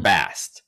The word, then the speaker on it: Bast: Bast uh, without the extra syllable at the end.